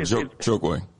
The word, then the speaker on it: Chokwe: Chalk, how you pronounce his name? – You're talking about Chokwe. Antoine Lumumba. – Say it again. – Chokwe.